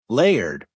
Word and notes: layered: American English

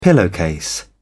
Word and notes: pillowcase: British English

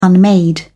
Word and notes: unmade: British English

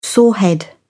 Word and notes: sorehead: British English

